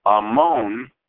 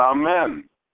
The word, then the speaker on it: amen: Do not pronounce it amon, but amen, the sustenance of all.